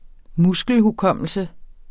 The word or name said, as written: muskelhukommelse